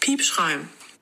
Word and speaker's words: Piepschuim: Das erste Wort ist Piepschreim.